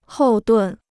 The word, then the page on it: 后盾: hòu dùn